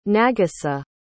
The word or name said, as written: nagisa